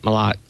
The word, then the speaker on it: Malat: Yeah, it's a French last name, but we like to pronounce it Malat.